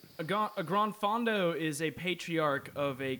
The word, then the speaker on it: granfondo: Granfondo. What is a granfondo for us? – Can you spell it? – GRANFONDO. – So it's not grandfondo. – Yeah, it's granfondo.